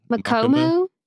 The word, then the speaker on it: Makomo: Makamo? Ghost children. – Makamo points out Mak. I feel like I'm saying that wrong. Makomo. Makomo.